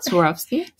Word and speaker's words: Swarovski: Swarovski. – Swarovski.